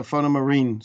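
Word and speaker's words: Fauna Marin: It's a the real name is without the E, but you can talk it fauna marine.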